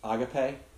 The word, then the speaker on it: agape: The fancy word is, I know you know this, agape. Do y'all talk about that? Do you pronounce it as agape or agape? – Agape is how I always heard it.